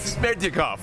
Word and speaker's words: Smerdyakov: Smerdyakov. – Smerdyakov.